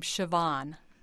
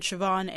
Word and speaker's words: Siobhan: And I have heard Siobhan as a common pronunciation, but Miss Brooks pronounces her name Siobhan. And so that's her name, and that's the way we say it on the air.